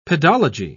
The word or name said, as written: pedology